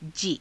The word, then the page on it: jii: Hindi